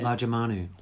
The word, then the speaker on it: Lajamanu: Lajamanu.